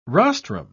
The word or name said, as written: rostrum